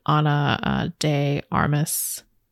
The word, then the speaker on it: Ana de Armas: and then Spawn, Knock Knock, which I really want to see, obviously stars Keanu Reeves and Ana de Armas. Definitely butchered her name, I'm sorry. – No, I think you got it right.